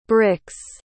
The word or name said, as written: brix